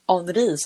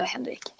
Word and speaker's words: Henrik: och det är faktiskt så att i en biografi så berättade hen Henri som du säger. Jag är inte lika bra på, Nej, men, på uttalet. Fast, fast i Danmark, då, man säger ändå Henrik.